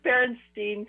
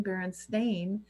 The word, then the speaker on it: Berenstain: Yeah, it was Berenstein for me. It right. Right. And now apparently you could never pronounce it that way because apparently it's always been Berenstain.